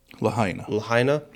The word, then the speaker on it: Lahaina: Even the people in Lahaina? Is that how you pronounce it? – Lahaina? Yeah.